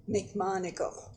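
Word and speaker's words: McMonagle: McMonigal. – Spell that for me. – M-C, capital M-O-N-A-G-L-E.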